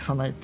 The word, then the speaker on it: さなえ: さなえというのは、まあ、早い稲と書いてさなえって読む、